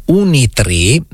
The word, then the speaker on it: Unitree: Si chiama Unitree, con le ultime due lettere si pronunciano I, ma in realtà sono due lettere E, quindi è scritto Unitree ed è quotata alla borsa di Shanghai.